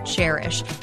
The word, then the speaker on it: Chairish: And this week's No Limits Entrepreneur is Anna Brockway, who is the co-founder and president of Chairish. And just so you know, it is spelled like a chair, C-H-A-I-R-ish, because it is a curated online marketplace for buying and selling vintage and used furniture.